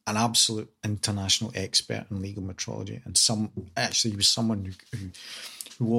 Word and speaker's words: metrology: We obviously need to clarify metrology these days in a way that we never thought we'd have to. People still mispronounce it as meteorology, which is very frustrating.